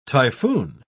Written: typhoon

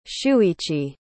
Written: shuichi

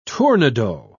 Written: tournedos

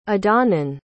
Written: adhamhnan

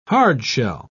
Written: hard-shell

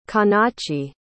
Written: canace

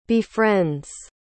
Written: befriends

